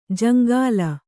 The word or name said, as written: ಜಂಗಲಿ